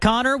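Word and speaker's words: Conor: You could also follow me on Twitter at Connor with one N, C-O-N-O-R, underscore green.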